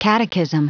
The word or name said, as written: catechism